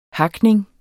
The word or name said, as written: HAKNING